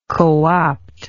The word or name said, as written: cooptive